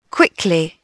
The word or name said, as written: quickly